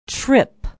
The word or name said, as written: tripp